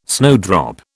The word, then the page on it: snowdrop: British English